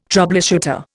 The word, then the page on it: troubleshooter: Indian